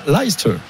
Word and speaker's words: Leicester: Et Paris recevra Leicester.